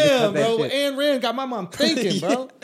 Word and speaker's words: Ayn Rand: It's A-Y-N, isn't it? – Yeah. – I thought it was And. – Oh, it's and Rand. – And Rand, yeah.